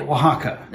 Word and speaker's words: Oaxaca: Oaxaca.